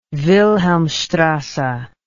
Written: wilhelmstrasse

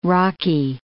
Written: rocky

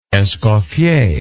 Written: escoffier

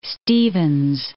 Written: Stevens Point

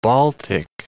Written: Baltic

